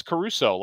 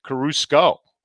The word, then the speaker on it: Caruso: I mispronounced Alex Caruso last week. I said Carusco.